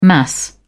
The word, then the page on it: mass: British English